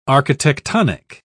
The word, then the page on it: architectonic: British English